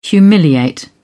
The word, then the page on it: humiliate: British English